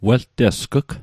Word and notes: weltesg'g: Mi'gmaq

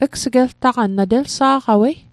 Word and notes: gs'geltaqan: Mi'gmaq

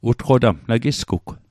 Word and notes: utqotamg: Mi'gmaq

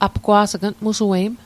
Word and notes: apgwa's'g: apkwa's'k; Mi'gmaq

